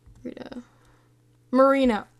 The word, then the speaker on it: Rita Moreno: Rita. Moreno.